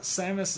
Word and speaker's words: Samus: Samus is S-A-M-U-S, like Sam.